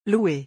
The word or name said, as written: louer